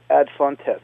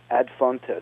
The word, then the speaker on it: ad fontes: And ad fontes is probably the best pronunciation of it today. Ad fontes, A-D, and then F-O-N-T-E-S, ad fontes, is to the sources.